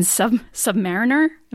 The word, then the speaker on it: submariner: What's funny is there's actually, there's an argument between submariners about whether you say submariner or submariner. I like submariner because it sounds better. I've been told by more submariners that it's actually submariner, but I'm like, whatever, potato, potato.